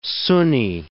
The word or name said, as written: Sunni